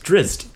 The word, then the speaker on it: Drizzt: Drizzt.